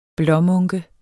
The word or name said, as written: blåmunke